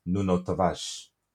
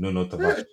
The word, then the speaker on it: Tavares: This new Twitter era, everyone's getting their pronunciations right, so it's Nuno Tavares. As everyone be saying it, man. Nuno Tavares.